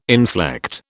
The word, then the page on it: inflect: British English